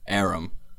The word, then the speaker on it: Aram: Aram.